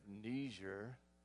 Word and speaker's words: Niger: Hey, if you've been around the church for a while, you've been praying for a missionary and aid worker in the country of Niger. Is that how you pronounce that, George? That's right.